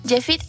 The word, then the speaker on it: Yefite: Jefit es mi otro nombre y se escribe Y-E-F-I-T-E.